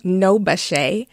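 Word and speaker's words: NOBCChE: We met at a chemistry conference, actually the National Organization of Black Chemists and Chemical Engineers, pronounced NOBACHE.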